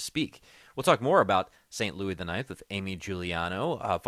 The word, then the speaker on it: St. Louis: St. Louis, pray for us. You know, in Cardinals country, they pronounce the S.